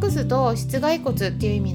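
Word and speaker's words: 膝蓋骨: で室外骨って読むん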